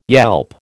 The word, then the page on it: yelp: American English